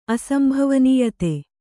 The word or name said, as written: ಅಸಂಭವನೀಯತೆ